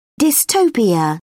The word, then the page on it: dystopia: British English